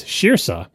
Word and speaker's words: Saoirse: So David says, my niece's name is Shirsa, which is spelled S-A-O-I-R-S-E, a name that you would never know the pronunciation of, except that it's also the name of a famous actress who's in a bunch of really good movies that you should definitely see.